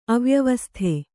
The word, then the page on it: ಅವ್ಯವಸ್ಥೆ: avyavasthe